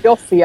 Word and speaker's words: Yoffe: And that just to sort of might round up things, that there's a quote again in the show notes by Emily Yoff or Yoffe. – Yoffe, I would pronounce it. – Yoffe, okay. So Emily Yoffe says, she's talking about cats and making them chase around by flicking a laser pointer around the room.